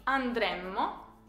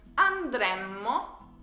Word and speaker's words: andremmo: Mi raccomando alla pronuncia della prima persona plurale, noi, perché, come avrete potuto notare, la differenza tra futuro e condizionale è soltanto una M. Dunque diremo andremo per il futuro e andremmo per il condizionale.